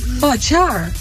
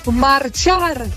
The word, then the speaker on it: Marchar: Sorry dat ik het zeg, maar jullie moeten echt wat doen aan de uitspraak van jullie nummer Magyar. Ik denk, Magyar, wat bedoelen ze nou? Ik snap hem niet, maar het is Marchar. – Oh. – De R moet beter worden uitgesproken, jongens. Marchar.